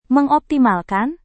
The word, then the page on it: mengoptimalkan: Indonesian